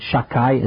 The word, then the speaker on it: Shakai: We don't pronounce it properly because it's one of the names of God. We pronounce it Shakai, as if the second letter was a Kuf, and it means Almighty.